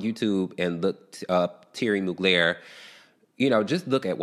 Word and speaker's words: Mugler: And I think I said that right. It's a Mugler. – Mugler. Yeah. – I don't know. – No, it is Thierry Mugler. That is how that is the official way to say it.